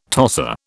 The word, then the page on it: tosser: British English